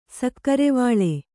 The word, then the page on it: ಸಕ್ಕರೆವಿಲ್ಲ: sakkarevilla